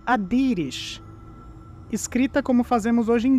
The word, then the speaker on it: Adiris: que indica que a pronúncia do nome, na verdade, é Adirish. Ou seja, Adiris antigamente se pronunciava Adirish e é assim que ela será chamada nessa nossa pesquisa de hoje.